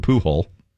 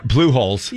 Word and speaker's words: Pujols: And Alex Rodriguez is behind the Pujol. – David's plural. – Pujols.